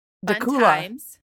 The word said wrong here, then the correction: Dacula: Wrong. Dacula.